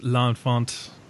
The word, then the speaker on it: L'Enfant: If I wanted to go the art house direction, there's Ellen Font, which is a lesser in my mind Dardenne brothers. – I believe movie it's pronounced L'Enfant.